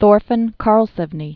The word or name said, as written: Thorfinn Karlsefni